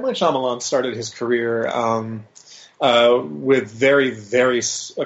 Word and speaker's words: Shyamalan: And am I pronouncing his name correctly? Is that Shyamalan? – Shyamalan. – Okay.